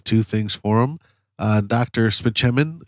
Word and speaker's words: Spaceman: And then she sees the prescription and it says Spaceman, although it's pronounced Spichemin.